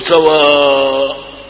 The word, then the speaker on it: سواء: لكن إذا ضممت سين فينبغي أن تنطق بها مقصورة بدون مد، إذا كسرت ينبغي أن تنطق بها مقصورة، بتقول سوى أو سوى، مو سوى، ما في سوى، ما في سوى. فإذا فتحت السين تنطق بها عندئذ ممدودة، تقول سواء.